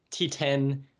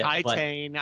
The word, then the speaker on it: Titane: T10, I think that's how the French say it. – Titane, I think, is how most people are pronouncing it here, and we've had discussions about that.